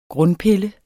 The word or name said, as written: grundpille